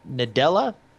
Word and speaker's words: Nadella: Satya Nadella? Is that close? – Sure.